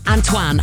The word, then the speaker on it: Antoine: How do we pronounce this one? Antoine. Hard Antoine. That's how we say it.